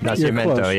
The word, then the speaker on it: Nacimiento: Lake Nacimiento, correct? Am I pronouncing that correct? – Nacimiento, yep.